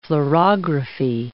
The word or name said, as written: fluorography